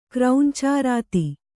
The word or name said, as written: ಕ್ರೌಂಚಾರಾತಿ